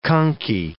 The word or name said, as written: conchae